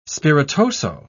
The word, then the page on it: spiritoso: British English